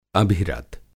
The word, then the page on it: अभिरत: Hindi